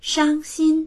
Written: shang1xin1

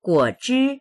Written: guo3zhi1

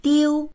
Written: diu1

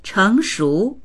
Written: cheng2shu2

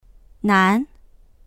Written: nan2

